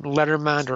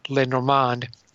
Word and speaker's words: Lenormand: But the name Lenormand or Lenormand is French.